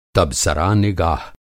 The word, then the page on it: तबसरानिगाह: تبصرہ نگاہ; Hindi